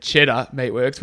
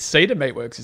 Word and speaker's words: Cedar Meatworks: Did I say that right? Because in pre-record, I said it quite embarrassingly. – Yeah, in our production meeting, said Cheddar Meatworks, which was obviously wrong, but I still think you said it wrong. I think it's Cedar Meatworks, isn't it? – Cedar Meatworks.